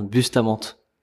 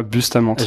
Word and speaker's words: Bustamante: Comment ton nom on le prononce comment pour être sûr ah, Mon nom ça se prononce Bustamante. Tu vois Bustamante.